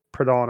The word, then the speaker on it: Proudhon: It's like Proud and then H Hun, like Proud Hun. Yeah, it's like Proudhon or whatever.